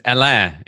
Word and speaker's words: Alain: My name is Alain. It's A-L-A-I-N, which isn't spelled the way it sounds in English.